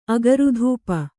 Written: ಅಗುರುಧೂಪ

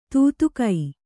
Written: ತೂತುಕೈ

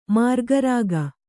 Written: ಮಾರಿಕಾರೋಗ

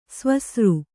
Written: ಸವಸರಿ

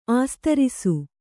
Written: ಆಸ್ತರಿಸು

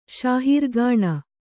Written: ಶಾಹೀರ್‍ಗಾಣ

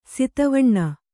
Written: ಸಿತವಣ್ಣ

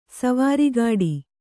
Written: ಸವಾರಿಗಾಡಿ

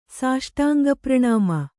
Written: ಸಾಷ್ಟಾಂಗಪ್ರಣಾಮ

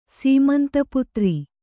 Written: ಸೀಮಂತಪುತ್ರ

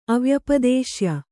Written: ಅವ್ಯಪದೇಶ್ಯ

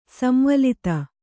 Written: ಸಂವಲಿತ

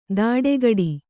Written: ತಡೆಗಡಿ

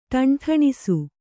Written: ಟೆಂಠಿಣಿಸು

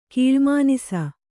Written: ಕಾಳ್ಮಾನಿಸ

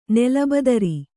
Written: ನೆಲಬದರಿ

